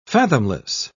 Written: fathomless

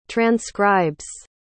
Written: transcribes

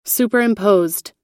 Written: superimposed

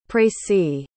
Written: précis

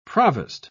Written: provost

